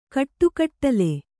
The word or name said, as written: ಕಾಡುಕಡಲೆ